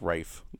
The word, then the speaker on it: Ralph: And it's not even Ralph, it's Rafe.